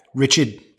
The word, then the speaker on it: Richard: Great to be here, Mike, and great to hear my name pronounced Richard, as we're both from around the city of Boston.